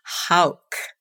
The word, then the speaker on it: Hauck: They say Hawk, but of course you know who. We've known each other for so long, and you say Hauck beautifully.